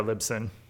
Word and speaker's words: Libsyn: Libsyn? I just had it open. I always forget how to say their name. Yeah, Libsyn.